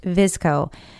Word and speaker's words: VSCO: As far as filters are concerned, there's an app called VSCO, which is pronounced Visco.